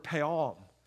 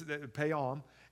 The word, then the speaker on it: peom: It's the word "peom," is how you say that. That's "peom."